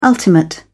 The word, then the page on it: ultimate: British English